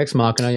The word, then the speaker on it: Ex Machina: I had Ava from Ex Machina. I don't even know if I'm saying that right. But Ex Machina, yeah.